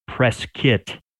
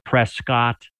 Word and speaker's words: Prescott: But um, shortly after we moved here, we we realized that the locals call it press kit, P R E S K I T. And that's how the locals pronounce it. If you call it Prescott, uh, people will know you're not from around here.